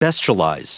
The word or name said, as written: bestialize